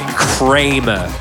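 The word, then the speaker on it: Chramer: I've been calling it Charmer for the last God knows how long, because I don't read properly. – I'm going to say Kramer. It's C-H-R-A-M-E-R. Kramer.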